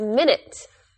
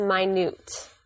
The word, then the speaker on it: minute: When you're talking about time, you say the word as minute. But when you are talking about something small or insignificant, you pronounce it as minute.